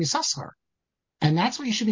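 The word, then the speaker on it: Yisachar (יששכר): According to the Masoretes, it's Yisachar. It's always Yisachar.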